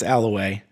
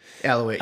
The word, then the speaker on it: Allouez: And the Z is silent. It's Alloway. – Alloway, yeah.